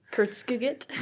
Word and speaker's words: Kurzgesagt: I know that, but Kurzgesagt? – Yes.